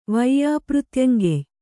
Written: ವೈಯಾಪೃತ್ಯಂಗೆಯ್

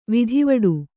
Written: ವಿಧಿವಡು

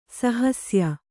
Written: ಸಹಸ್ಯ

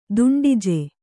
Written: ದುಂಡಿಜೆ

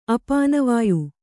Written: ಅಪಾನವಾಯು

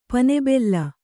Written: ಪನೆಬೆಲ್ಲ

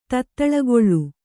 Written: ತೊತ್ತಳಿಗೊಳ್

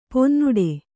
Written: ಪೊನ್ನುಡೆ

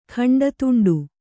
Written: ಖಂಡತುಂಡು

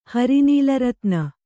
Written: ಹರಿನೀಲರತ್ನ

